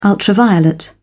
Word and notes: ultraviolet: British English

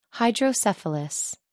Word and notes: hydrocephalus: American English

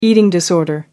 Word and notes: eating disorder: American English

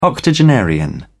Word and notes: octogenarian: British English

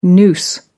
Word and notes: noose: American English

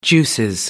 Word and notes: juices: British English

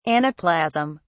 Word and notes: anaplasm: British English

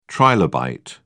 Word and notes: trilobite: British English

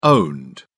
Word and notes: owned: British English

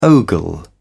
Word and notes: ogle: British English

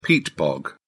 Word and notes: peat bog: British English

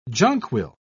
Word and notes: jonquil: British English

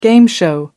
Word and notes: game show: American English

